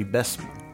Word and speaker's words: Bessemer: The fantastic chosen gospel singers of Bessemer, Alabama, or as the locals say, Bessemer.